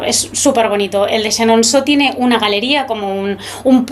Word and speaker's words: Chenonceau: Bueno, fuera de antena me has dicho que no debería perderme el castillo de Chenonceau. – Chenonceau, muy bien, muy buena la pronunciación, ¿eh? – Bueno, genial, sí.